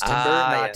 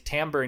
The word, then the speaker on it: timbre: A lot of people were like, oh, like, because they think T-I-M-B-R-E is pronounced timber, timbre.